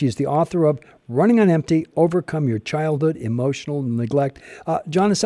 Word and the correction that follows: Janice: am I pronouncing your first name correctly? – It's actually Janice, but I get called Janice.